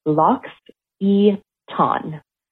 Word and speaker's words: L'Occitane: So the way you pronounce the company is Lox E Ton. So Lox, like bagels and lox. bright E, E Ton, like a dark off.